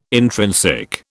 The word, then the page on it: intrinsic: British English